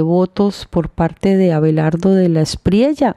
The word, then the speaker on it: de la Espriella: Pero como estamos en Latinoamérica, donde la doble L, la Y, se pronuncia como Y prácticamente, entonces de la Espriella.